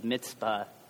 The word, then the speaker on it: Mizpah: The good I was giving you is the pronunciation of Mizpah, which is actually the good Hebrew pronunciation, not that Gedaliah and people were assassinated.